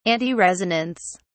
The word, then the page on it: antiresonance: British English